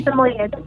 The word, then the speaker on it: Samoyed: He's a Samoyed. – He's a what? – A Samoyed. – A Samoya? Like the Girl Scout cookie? – Samoyed. I'll spell it in the chat for you.